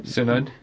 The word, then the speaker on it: Synod: Well, it's very interesting, and also the invitation of Pope Francis to be on, how do you say that, David? Synod? – Synod, yes.